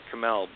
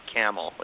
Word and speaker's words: Cammell: I grew up pronouncing it Cammell, but I've learned that it's actually pronounced Cammell.